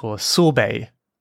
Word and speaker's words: sorbet: I think this year I had one and it was a sorbet. Sorbet, I guess it's a French word. It looks like sorbet, but the T is silent, like many French words, like ballet, buffet.